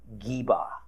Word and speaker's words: Gibeah: In this case, what we in English say as Gibeah is actually more pronounced something like Geba, Geba.